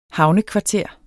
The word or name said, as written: havnekvarter